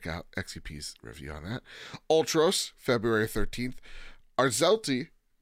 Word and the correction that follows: Arzette: Arzette, thank you.